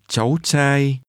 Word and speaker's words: cháu trai: Một cháu trai. Chai, chai, cháu cháu trai cháu trai Son is pronounced with rising and mid-level tones.